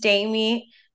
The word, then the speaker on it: Diami: Hi, my name is Diami. It's like Amy, but with a D.